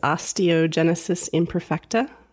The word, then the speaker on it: Osteogenesis imperfecta: I will never be able to pronounce the actual disease. Osteogenesis imperfecta. – There you go. You got it.